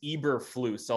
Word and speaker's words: Eberflus: Coach Eberflus. Did I get that correct? I've been struggling all week to figure out how you say it. – You got that correct. It is Eberflus.